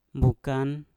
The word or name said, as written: mbukan